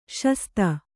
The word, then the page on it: ಶಾಸ್ತø: śastř